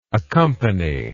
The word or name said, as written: accompanier